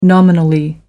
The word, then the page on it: nominally: American English